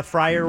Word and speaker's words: Frier: Thanks to Brad for the category about our good friend Bob Fryer. Fryer rhymes with Dyer.